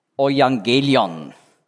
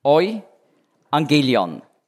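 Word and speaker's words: Euangelion (εὐαγγέλιον): Evangelium ist einfach die lateinische Aussprache des griechischen Wortes, das hier im Grundtext steht, Euangelion. Euangelion.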